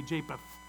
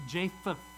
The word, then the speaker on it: Japheth: I hear a lot of people say Japheth. No, it's Japheth.